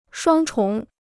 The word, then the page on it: 双重: shuāng chóng